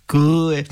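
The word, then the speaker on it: Cauuet: Et il y a Paul Coet, je pense, je sais pas comment le dire. Enfin, bref. – Qui ça s'écrit comme Coet avec un U de plus. – Oui, c'est ça. – Coet.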